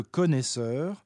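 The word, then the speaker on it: connoisseur: Le vieux mot de « connaisseur » a gardé en Angleterre sa prononciation à la manière de l'ancien français, mais c'est bien avec un « o » et non un « a » qu'il s'écrit.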